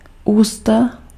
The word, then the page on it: mouth: /ˈmaʊθ/